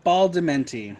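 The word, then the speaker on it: Badalamenti: Angelo Maglamenti, however you say his, how do you say his last name? – Angelo Baldamenti. – Baldamenti, that's it.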